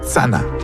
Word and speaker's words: tsana: Par exemple, pour dire « arrêt » en Abenaki, on dit « tsana ». En Abenaki, ça s'écrit « c-h-a-n-n-a », mais il n'y a pas le son « ch ». À la place, on utilise le son « ts ». Et donc, ça donne « tsana ».